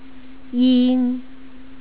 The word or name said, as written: ying